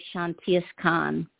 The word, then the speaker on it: Shantiaskan: Okay, the way I pronounce it is Shantiaskan.